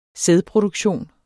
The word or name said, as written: sædproduktion